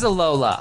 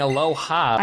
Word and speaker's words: Alola: Oh, it is Alola. Oh, I... – You've been saying it wrong the whole time. – I've been saying Aloha.